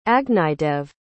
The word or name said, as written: agnidev